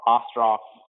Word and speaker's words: Ostroff: And obviously, you know, Ostroff is what it sounds like, but you're not necessarily going to spell it that way just hearing it.